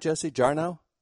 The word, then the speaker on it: Jarnow: And am I pronouncing that right, Jesse Jarnow? – Yep, definitely.